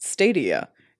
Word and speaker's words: Stadia: Fun fact: the reason it's pronounced Stadia is because Stadia is the plural form of stadium.